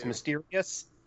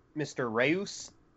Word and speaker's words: Mr. Rios: It was Mysterious. Because when I read it, I pronounced it like Mr. Reus.